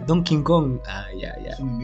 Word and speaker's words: Donkey Kong: Cuando estábamos hablando de la Nintendo 64 que dijo, que dijo, dijo Donkey Kong en vez de Donkey Kong, que es la pronunciación correcta en inglés.